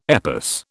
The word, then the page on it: EPOS: British English